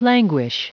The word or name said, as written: languish